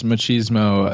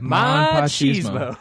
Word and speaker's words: machismo: I like that you pronounce machismo as if it's, like, ma and pa kettle. – What do you mean? – Machismo. Like, ma and pa chismo.